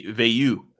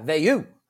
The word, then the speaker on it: Veilleux: Veilleux. – Yeah, Veilleux.